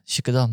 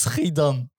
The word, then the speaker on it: Schiedam: Meneer, dat is Schiedam. Dus die meneer het was gewoon Turkse oude opa die dus Schiedam niet kon uitspreken. Hij zei altijd Shukadam.